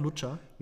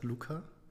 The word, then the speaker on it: Luca: Äh, heißt es auch Lucha? Nein, es heißt Luca.